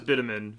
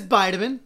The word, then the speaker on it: Nahabedian: I thought it was Bitumen forever.